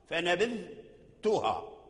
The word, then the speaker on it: فنبذتها: فنبذتها هكذا، فنبذتها أي تنطق بالذال ثم ترجع بلسانك إلى وصول الثنايا فتخرج التاء من مخرجه.